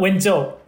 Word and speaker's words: Wenzhou: Uh, Wenzhou, Wenzhou.